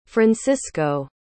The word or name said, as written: francisco